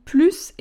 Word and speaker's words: plus: Donc là, on va prononcer le S il a plus de voitures, il a plus de chiens, il a plus de routes à faire que son copain.